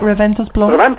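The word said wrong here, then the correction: Raventós i Blanc: Reventos y Blanc. – Reventos y Blanc. – Yeah, there's an I between Reventos and Blanc.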